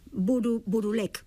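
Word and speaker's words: Bouroullec: tanto como son os irmáns Burulek, creo que así o de pronunciado.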